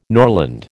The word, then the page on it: norland: American English